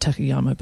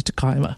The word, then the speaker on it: Takayama: There's Gifu, the main city, but then there's another town called Takayama, which actually is realistically pronounced Takayama. – Right. – We were calling it Takayama, but it's actually Takayama.